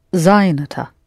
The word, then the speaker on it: занята: Я сказала, что я занята. – Mm-hmm. Only with... The feminine of this word, the accent falls on the ending.